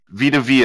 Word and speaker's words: Vita Vea: Vita Vea, That's a cool name.